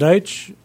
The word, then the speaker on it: Deutsch: This, name is Jim Deitch. Last name is spelled D-E-U-T-S-C-H.